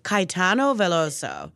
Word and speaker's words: Caetano Veloso: Caetano Veloso.